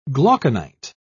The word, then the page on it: glauconite: British English